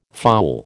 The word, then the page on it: fowl: American English